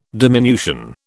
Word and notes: diminution: British English

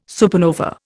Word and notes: supernova: Indian

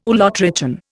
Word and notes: ulotrichan: Indian

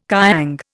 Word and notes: kiang: Indian

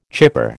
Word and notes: chypre: American English

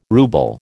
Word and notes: ruble: American English

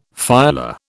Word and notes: phyla: British English

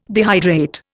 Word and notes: dehydrate: Indian

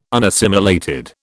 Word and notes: unassimilated: British English

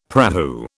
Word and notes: prahu: British English

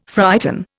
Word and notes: frighten: Indian

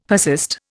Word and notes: persist: Indian

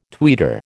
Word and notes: tweeter: American English

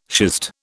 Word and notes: schist: British English

